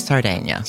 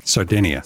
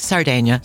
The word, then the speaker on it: Sardinia: See, so, first, I'd like to explain that that is the correct pronunciation of the island, Sardinia. That's Italian. Most English people speaking call it, pronounce it Sardinia. Sardinia. Mm-hmm. That's not right. No, so it's Sardinia. Sardinia.